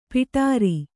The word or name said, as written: ಪೌಡರು